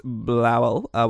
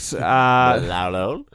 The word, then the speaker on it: Blauel: Her name was Renate Blauel, which I've pronounced exactly correct. – Blauel.